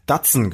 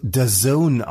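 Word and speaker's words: DAZN: Das wird The Zone ausgesprochen. The Zone. The Zone.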